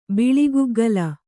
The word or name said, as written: ಬಿಳಿಗುಗ್ಗಲ